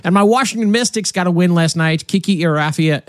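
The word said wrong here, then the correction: Iriafen: Kiki Irafin.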